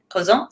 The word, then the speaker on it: cousin: So cousin. Even though it ends in an N, cousin, say it again. – Cousin. – Did I say it right? – Yeah. – Cousin. And then cousine. So the feminine, because it has the E on the end, I'm going to say that.